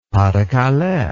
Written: pas de calais

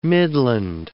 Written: midland

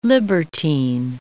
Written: libertine

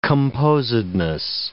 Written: composedness

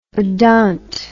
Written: odont

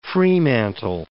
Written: Fremantle

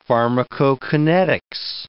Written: pharmacokinetics

